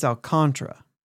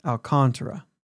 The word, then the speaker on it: Alcantara: But I think it's Alcantara. It is Alcantara. – Yeah. – The emphasis is on the con.